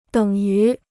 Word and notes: 等于: děng yú

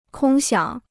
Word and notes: 空想: kōng xiǎng

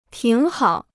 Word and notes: 挺好: tǐng hǎo

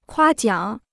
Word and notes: 夸奖: kuā jiǎng

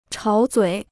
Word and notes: 吵嘴: chǎo zuǐ

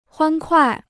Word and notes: 欢快: huān kuài

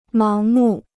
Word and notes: 盲目: máng mù

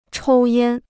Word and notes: 抽烟: chōu yān

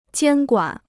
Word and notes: 监管: jiān guǎn

